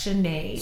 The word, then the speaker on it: Sinead: As some backstory, Sinead, is that how you say her name? – Yes, Sinead.